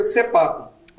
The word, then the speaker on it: CPAP: É uma sigla, né? CPAP, a gente pronuncia CPAP.